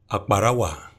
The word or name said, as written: Akparawa